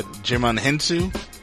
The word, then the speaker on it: Djimon Hounsou: Uh, yeah, Djimon Hounsou. That's how I say it, Djimon Hounsou.